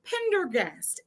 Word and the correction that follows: Prendergast: And I think I said that right. Is that Prendergast? – There is the second time.